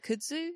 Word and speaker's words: kudzu: Oh, is it like, is it kudzu? Is that how that's pronounced? – Yeah. – Kudzu, yeah.